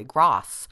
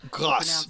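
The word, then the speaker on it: grâce: Another one is coup de grace is what people say, C-O-U-P de grace, it's spelled. But it's not pronounced coup de grace. You think it should be, but it's coup de grace, really. Grasse. – Grasse, yes.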